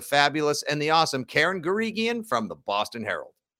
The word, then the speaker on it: Guregian: And this week, it's Boston Herald legend Karen Garigian, who I just learned it's a hard G.